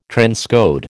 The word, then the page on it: transcode: American English